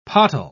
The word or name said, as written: pottle